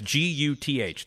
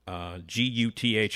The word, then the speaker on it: Guth: And that's Guth. That's the last name. Guth. And as you say, rhymes with truth.